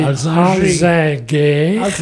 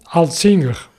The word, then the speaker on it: Alzinger: La tendance à la française, c'est de prononcer Alzinger, ou à l'allemande, Altzinger. – Ça me fait penser à un autre qui a mieux réussi, qui s'appelait Ratzinger. – C'est pas loin, hein. – Exactement. Très probablement, l'abbé connaissait-il l'allemand, donc pour son cas particulier, les deux prononciations auraient été acceptables, mais j'ai quand même tendance à penser qu'il aurait préféré l'appellation francophone par rapport à la prononciation germanophone, oui. Donc disons Alzinger.